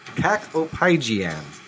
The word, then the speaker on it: kakopygian: So this is an adjective, and the word is cacopygian. Cacopygian. That is spelled K-A-K-O-P-Y-G-I-A-N.